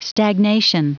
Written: stagnation

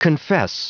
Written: confess

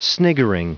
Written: sniggering

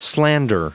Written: slander

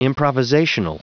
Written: improvisational

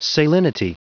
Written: salinity

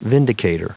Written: vindicator

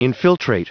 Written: infiltrate

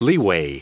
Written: leeway

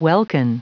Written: welkin

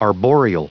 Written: arboreal

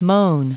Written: moan

